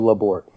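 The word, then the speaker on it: Labourt: Jairo Labor, yes. Yes, you do pronounce the T.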